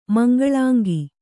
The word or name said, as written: ಮಂಗಳಾಂಗಿ